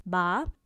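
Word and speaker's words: ba (八): So for an example, 8 has long been regarded as the luckiest number in Chinese culture because the pronunciation of 8 in Chinese is ba, and that is a word that sounds similar to the word fa, which means make a fortune.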